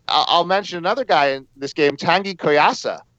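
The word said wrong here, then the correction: Kouassi: Or how we say that name? Tanguy Koyasi or something. – Yeah, we'll go with that. You're close enough. – Pronunciation. Koyasi. Yeah, I don't know why I was saying it weird.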